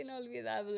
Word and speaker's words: Inolvidable: I don't know why I keep thinking intocable, but it's literally inolvidable. It's inolvidable. That's what it is.